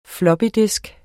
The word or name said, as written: floppydisk